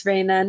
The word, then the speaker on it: Reina: Am I, am I pronouncing it correctly, Reina? Yes, you are pronouncing it right. It's Reina.